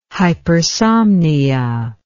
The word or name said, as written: hypersomnia